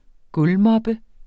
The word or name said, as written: gulvmoppe